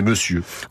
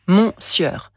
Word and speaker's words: monsieur: Il y a le mot que l'on emploie tous les jours, c'est monsieur. – Oui, à l'écrit, on lit mon sieur. Mais à l'oral, cela donne monsieur.